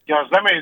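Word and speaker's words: gözleme: He first of all went to Trabzon and fell in love with Gözlemi. Am I pronouncing that correctly? – Gözlemi is right, but I'm very surprised that he went to Trabzon because it's like that.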